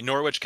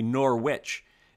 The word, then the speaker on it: Norwich: All right, the Norwich Cadets are up next. – Yeah, GNAC Conference, Northfield, Vermont. I believe you said it correctly, Norwich. It's not like the Premier League side, which says Norwich.